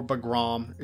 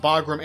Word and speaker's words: Bagram: Bagram, or Bagram, it's pronounced both ways, but Bagram Air Base was a centerpiece of the American occupation of Afghanistan as well.